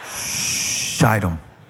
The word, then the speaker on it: Shittim: Then Joshua, son of Nun, secretly sent two spies from Shittim. It's a long I. Very important. Shittim.